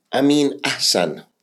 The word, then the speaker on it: Ameen Ahsan: Today I have a business specialist, a gladiator in Ameen Ahsan.